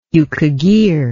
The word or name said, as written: yukaghir